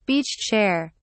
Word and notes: beach chair: British English

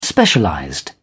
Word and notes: specialized: British English

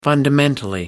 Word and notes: fundamentally: American English

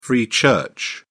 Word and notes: free church: British English

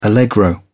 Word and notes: allegro: British English

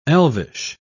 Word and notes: elvish: British English

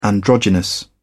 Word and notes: androgynous: British English

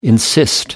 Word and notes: insist: American English

